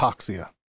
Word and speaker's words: hypoxia: It is hypoxia.